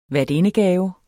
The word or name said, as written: værtindegave